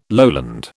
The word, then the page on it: lowland: British English